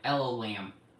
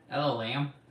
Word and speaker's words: El Olam: How do I say it? El Olam. – It sounds like you said, hello, lamb. – El Olam. – That's like, you just switched to Southern on me.